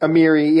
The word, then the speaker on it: Amiri: The Badr Brigade led by, what's his name, Amirli, or how do you say it? – Amiri, yeah.